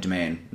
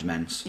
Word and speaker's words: Demesne: But yeah, the Malahide Demesne, not Demesne.